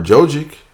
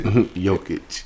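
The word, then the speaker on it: Jokić: Or maybe Murray from Denver, or Jokic. – That's how you pronounce it. – Jokic. – Yeah. The J is really a Y. – Jokic.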